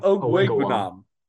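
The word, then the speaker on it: Okwuegbunam: Ozu Hawajiwe or something? – Not even close. – I thought that was pretty close. – Thank you. – No, it wasn't. – It's like Ogwegbenam. Ogwegbenam. – That sounds awfully close to what I just said. – Your pronunciation had 18 L's in it. – Yeah, you had like 40 vowels in that shit.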